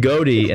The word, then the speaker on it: Goede: I'm going with Ryland Goede, and that's the official pronunciation, by the way, just to prove to you that I know what it is.